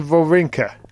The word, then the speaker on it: Wawrinka: Wawrinka's, pronounce his name right. – Stan Wawrinka.